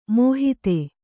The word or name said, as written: ಮಾಹತ್ತು